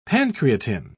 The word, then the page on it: pancreatin: British English